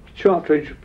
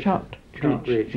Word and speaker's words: Chartridge: Chartridge, spelled as it's pronounced, yes. – Is it? – Chartridge.